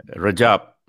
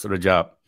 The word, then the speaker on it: Rajab: Rajab. Simpler. Don't use the complicating words. Rajab.